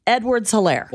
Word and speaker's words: Edwards-Helaire: No, it's Edwards-Helaire. It all goes as one. Edwards-Helaire.